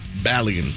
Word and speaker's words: Balogun: In a secondary story, the World Cup tournament is continuing, and striker for the United States, Bulgarian. I said it wrong. Say it for me. Foreign. – Balogun. – Balogun? – I think so. – Balogun. Foreign Balogun.